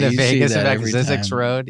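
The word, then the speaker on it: Zzyzx: Zix, the alphabetically last town name in the United States of America, is located in this state.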